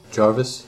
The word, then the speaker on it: Jervis: How do you spell that? – J-R-V-I-S, the Australian Naval Base. – Jarvis? – Jarvis. – Right. – Okay. Jarvis Bay, excuse me.